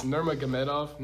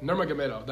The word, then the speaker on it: Nurmagomedov: I mean, yeah, I don't know how to say his last. It's Nurmagomedov. Nurmagomedov, that's how I say it.